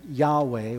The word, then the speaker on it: Yahweh: And it's usually written and pronounced as Yahweh, Y-A-H-W-E-H, if we want to spell it phonetically. But the real form is just the Hebrew consonants that we would translate as Y-H-W-H.